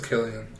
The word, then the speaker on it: Cillian: I thought it was Killian Murphy. Which is it? Is it Cillian? – I believe it's Cillian.